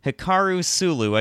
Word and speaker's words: Hikaru Sulu: He was the helmsman Hikaru Sulu.